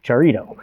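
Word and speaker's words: charito (χαριτόω): It's the Greek word, and I'm probably going to pronounce this, but it looks like it's charito, kind of like a burnt Dorito, charito.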